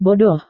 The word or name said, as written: bodoh